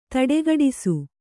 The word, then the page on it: ದಟ್ಟಿಗಟ್ಟಿಸು: daṭṭigaṭṭisu